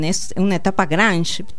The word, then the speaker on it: grunge: ¿Grunge y noise? ¿Lo estoy diciendo bien? – Sí. – Se dice grunge igual. – Grunge. Claro, se dice grunge.